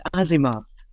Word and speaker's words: Asimov: Is that how you say it? Asimov? I think it's Asimov. – That sounds right, yeah.